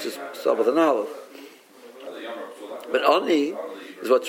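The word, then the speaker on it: oni (עני): There's no vav in the word. It's pronounced oni, but oni ksib. It's written as lachem oni.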